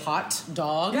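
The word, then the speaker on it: Haute Dog: There's a show called Hot Dog, it's spelled H-A-U-T-E, like haute couture, yeah. – except for they say it, Hot Dog.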